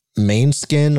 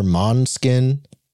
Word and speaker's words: Måneskin: Maneskin or Måneskin.